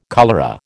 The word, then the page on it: cholera: American English